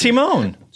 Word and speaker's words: Timon: You just came up with another pronunciation. – No, it's Timon.